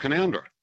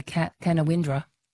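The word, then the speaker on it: Caloundra: Oh, the classic would be a bit north of here, well, fine town called Canoundra. – Oh, people say Canawindra.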